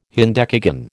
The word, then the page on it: hendecagon: American English